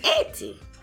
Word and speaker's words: Haiti: No, that's how they say it. It's Haiti. Go ahead.